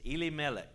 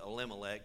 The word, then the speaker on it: Elimelech: And the name of the man was, and I'm going to pronounce it the way the Hebrew is Elimelech. Elimelech. We'll Americanize it, call it Elimelech, but the Hebrew is Elimelech.